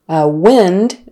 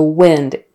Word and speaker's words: wind: So wind, with a short I, wind. Wind is the verb, wind, and that's with a long I, wind.